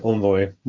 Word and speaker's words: Envoy: And I think the important thing here is that the container uh, that runs as a sidecar is this proxy called Envoy, or my American colleagues pronounce it Envoy, more like the French, I guess.